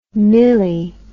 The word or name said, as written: milli